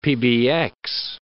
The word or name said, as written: PBX